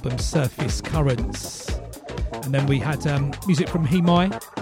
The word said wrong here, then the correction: Hemai: Actually, it's pronounced Hemai. I've just recently found out. I've been pronouncing it wrong, even though we signed him on True Thoughts. Spelled H-E-M-A-I.